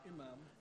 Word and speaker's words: imam: Is it called an imam? What? Yes, I have trouble pronouncing it. Say it loud. Imam.